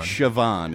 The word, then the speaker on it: Siobhan: And Siobhan Fahey taught me how to pronounce Siobhan, the Irish name Siobhan, which when you read it looks like Siobhan. – It's like, who is Siobhan Fahey? – Siobhan. – Siobhan is how you pronounce it.